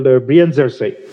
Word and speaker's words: Brienzersee: And the other one is called Brienzersee. – Okay. – So say S-E-E, it's pronounced say, it means lake in German.